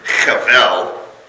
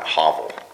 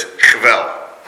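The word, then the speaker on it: chevel (הֶבֶל): I mean, if you think about it, and again, this vanity, we're going to get into the, you know, this is the Hebrew word chevel. Right? They, if you watch the Bible Project, they mispronounced it. They just kept calling it hovel or hevel. Yeah, it's a guttural. The reason he did that is because we don't like gutturals. But it's chevel.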